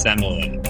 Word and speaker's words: Semele: Yeah, and it's actually pronounced Semele. – I'm actually going to Semele. – See, I don't know. – Yeah. It's pronounced Semele, but it's definitely an opera. If you know that it's pronounced that way, it's probably an opera.